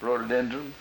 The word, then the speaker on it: rhododendron: Rhododendron.